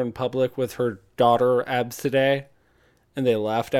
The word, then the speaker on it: ABCDE: Absidae. The spelling is ABCDE.